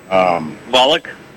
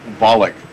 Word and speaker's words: Volokh: Volokh. – Volokh.